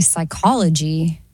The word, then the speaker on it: psychology: The P is silent in psychology.